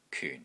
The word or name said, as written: KYUN4